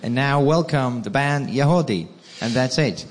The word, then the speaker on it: Yagody: Y-A-G. O-D-Y, but it's pronounced Yahodeh.